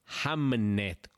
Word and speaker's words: Hamnet: Y lo estoy pronunciando bien, Hamnet, con N de Navarra, no Hamlet con L de León.